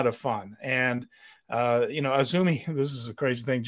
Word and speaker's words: AZM: As you can see on the screen, Azumi's name is spelled A-Z-M. – Yes. – A-Z-M is how it looks every time you see it written out, but it is, in fact, Azumi.